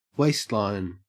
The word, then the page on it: waistline: Australian English